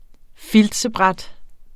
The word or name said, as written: filtsbræt